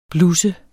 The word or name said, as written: blus